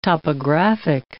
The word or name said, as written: topographic